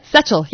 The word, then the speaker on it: Setchell: E-L-L. – E-L-L. Whoops. How do you say Setchell? – Setchell, yes.